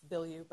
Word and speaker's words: Bilyeu: It's Bielou, by the way. – Whenever I say your name, I slip into like kind of a really bad Midwestern person's version of a New Orleans accent. – Yeah, it's not the French way, and either way, it just goes away.